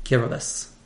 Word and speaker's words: Kiribati: And this pronunciation seems bizarre until you learn that in the Gilbertese language of Kiribati, TI is pronounced S.